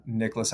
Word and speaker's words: Nicklaus: But yeah, Nicholas Hasselberg on LinkedIn.